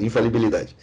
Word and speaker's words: infalibilidade: infalibilidade, isso falei errado. – Tá certo, infalibilidade.